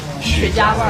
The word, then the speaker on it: 茄: 我怕呛的人肯定不行，这里面是很浓的雪茄味。 – 雪茄的茄是个多音字，除了茄子的茄这个读音啊，古语当中的一声的茄的意思呢，是指荷叶的茎干。